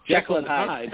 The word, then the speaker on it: Jekyll and Hyde: I don't think I've seen Yankee teams this heckle and jive. Jekyll and Hyde.